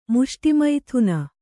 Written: ಮುಷ್ಟಿಮೈಥುನ

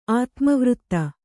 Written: ಆತ್ಮವೃತ್ತ

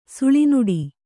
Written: ಸೋಲ್ನಡೆ

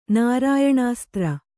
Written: ನಾರಾಯಣಾಸ್ತ್ರ

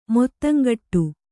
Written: ಮಾತುಂಗುಟ್ಟು

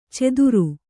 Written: ಚೆದುರು

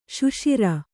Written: ಸಸಾರ

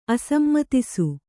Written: ಅಸಮ್ಮತಿಸು

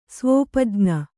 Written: ಸ್ವೋಪಜ್ಞ

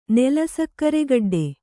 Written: ನೆಲಸಕ್ರೆಗಡ್ಡೆ

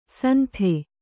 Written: ಸಂಥೆ

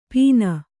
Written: ಪಿನ್

